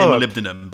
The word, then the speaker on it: molybdenum: I'm proud of myself for knowing how to say molybdenum, by the way.